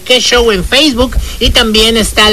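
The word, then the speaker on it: Que Xow: Ahí Que Show, por cierto, Que Show nos encuentras así como suena, Que, Q, U, E, y Show se escribe X, O, W. – Así. – Que Show.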